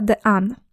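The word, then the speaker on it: Dehaene: 4 filary uczenia się wzięłam z książki Stanislasa de Anne. To jest Francuz, więc w jego nazwisku jest bardzo dużo literek, których się nie wymawia. Przeliteruję to nazwisko dla porządku. Stanislas de Anne. D-E-H-A-E-N-E. D-H-N-E.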